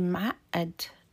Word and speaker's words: معقد: So when you say معقد, the U of مو is removed and the قاف is pronounced as a همزة. This is a trait of the Levantine dialect.